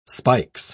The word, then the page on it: spikes: British English